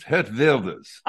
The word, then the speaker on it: Geert Wilders: Dutch is very much like German. W is pronounced like V and the G isn't pronounced. It's Geert Wilders.